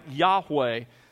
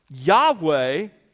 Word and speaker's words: Yahweh: It was probably pronounced something like Yahweh, the best we can put it back together.